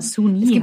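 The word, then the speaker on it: Soon-Yi: Also, ja, Suni heißt die.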